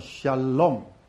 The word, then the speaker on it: shalom: 所以所罗门王的字的根就这个字，叫做 shalom。好，第一个字念作 sha，第二个字念作 l，第三个念作 o，第四个字念作 m。所以 shalom， shalom 犹太人见面都是 shalom，阿拉伯人见面，我去沙地的时候他们叫做 salam，但是我们今天翻成都翻成 peace，其实 shalom 有什么意思我这边还没有写完，你就知道神拣选这个民族是很奇妙的。